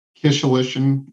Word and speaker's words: Kishelin: And I know I've pronounced this before, and I'll get it wrong again today, and I'm sure counsel will correct it, but Kishelin, I believe, is the pronunciation. – Kishelin, and it took me 2 years, Your Honor, so you're in good company. – I missed two entire syllables, but thank you for that correction.